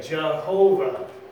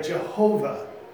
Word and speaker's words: Jehovah: The American Standard wrote it this way, Jehovah. See that? There's the H, the W becomes a V, and the H is there. They put in the vowels there, Jehovah.